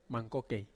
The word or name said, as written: mangkokei